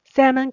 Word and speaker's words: salmon: Salmon chaos 就是我们所谓的鲑鱼之乱。 S A L M O N 鲑鱼，那这里的 L 小心是没有发音的，它是念成 salmon salmon。